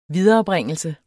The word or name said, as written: viderebringelse